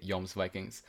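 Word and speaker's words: Jomsvikings: Well, it's written with a J, and I don't, obviously in English, J is J. But well, yeah. – But it's never, it's yeah in all of the Scandinavian languages, which is why I'm pronouncing it Yom's Vikings.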